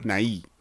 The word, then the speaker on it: Nighy: His last name is spelled Nighy. – Yeah. – This one. – Nye.